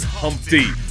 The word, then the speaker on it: Humpty: My name is Humpty, pronounced with a umpty.